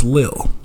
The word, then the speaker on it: Lil: little, it's Lil.